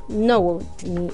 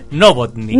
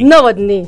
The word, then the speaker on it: Nowotny: dijo Nowotny. Oye, lo pronuncié pésimo. – ¿Cómo? Mira, a mí me pasó lo mismo en la mañana. Es Nowotny. – Nowotny. – Nowotny. Nowotny. – Todo mal, Nowotny.